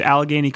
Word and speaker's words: Allegheny: I learned, for example, that you went to Allegheny College.